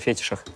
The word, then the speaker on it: фетиш: Мне ну, это уже, блин, если вам такая нравится тема, это, короче, мне кажется, уже разговор о фетишах. О фетишах. Вот. Так правильно. Посмотрите ударение. Фетиш. Правильно, вот.